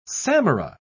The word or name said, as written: samara